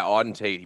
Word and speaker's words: Auden Tate: is it Auden Tate? Is that how you pronounce the? – Yeah, Auden Tate.